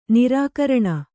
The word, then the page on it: ನಿರ್ಘೃಣ: Kannada